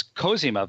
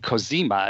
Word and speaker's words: Cosima: and it's Cosima Wagner, but people kept saying Cosima as if she were Italian. So I guess they didn't even do that much research. For the record, Cosima was played by Claire Brownell,